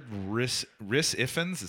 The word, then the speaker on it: Rhys Ifans: I've heard Rhys Ifans, is that how you say his name? – Yeah.